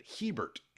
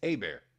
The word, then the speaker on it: Hebert: transferred to our school, and his last name was in Canada Hebert and that's how he pronounced it. Hebert, H E B E R T. In Louisiana, it's Bear.